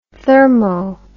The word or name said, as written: thermo